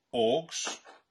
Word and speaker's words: AUX: And John, we've got you on to talk about your new film, AUX.